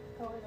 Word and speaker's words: Kolyan: I can't Koleon, what's the spelling for that? Koleon Indirovich. K-O-L-Y-A-N. – Yep. – Indirovich. I-N-D-I-R-O-V-I-C-H. – V-I-C-H? – Yes.